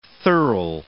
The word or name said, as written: thurl